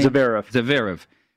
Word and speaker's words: Zverev: How do you say the name? – Zverev.